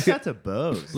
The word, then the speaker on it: Bose: How do you say that? Bose speaker.